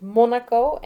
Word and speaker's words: Monaco: Kijk, bij ons in Nederland ligt de klemtoon heel anders. In België is het Monaco en in Nederland is het Monaco.